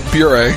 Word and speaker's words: Bure: How did I pronounce? – Bure. – Pavel Bure.